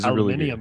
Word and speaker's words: aluminium: In Russellville works at Walmart or the he's aluminium really factory. – Aluminium, yeah, that's how you say it.